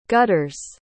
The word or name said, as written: gutters